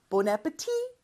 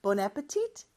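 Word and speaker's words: bon appétit: 그래서 원래 프랑스어처럼 하려면 마지막 T를 발음하면 안 돼요. Bon appetit! 그런데 미국에서는 주로 T를 발음해서 Bon appetit! 이렇게 하는 경우가 있다고 했었죠. What's for dinner?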